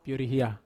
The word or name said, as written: piorihia